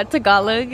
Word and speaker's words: Tagalog: Yeah, Tagalog. – Tagalog.